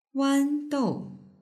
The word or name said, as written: wandou